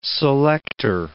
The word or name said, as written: selector